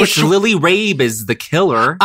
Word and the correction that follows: Lily Rabe: I think, well, you know, that's pronounced Lily Rob. – Lily Rob is the killer. Broccoli. – Like Broccoli Rob.